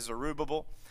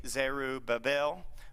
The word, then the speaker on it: Zerubbabel: They were led by a man, depending on how you like to pronounce it. I'm Southern, so I like to say Zerubbabel. Some may say Zerubbabel.